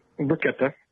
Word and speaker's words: bruschetta: Uh, how, how's it properly pronounced? Bruschetta or bruschetta? – Bruschetta.